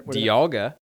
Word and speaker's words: Dialga: You butchered that. – All right. Tell me what's their... – Dialga. – Okay. – And Palkia. – Ver, that's how you pronounce those.